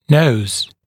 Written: nose

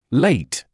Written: late

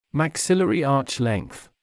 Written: maxillary arch length

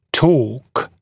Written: torque

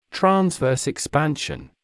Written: transverse expansion

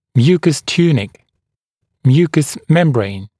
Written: mucous tunic mucous membrane

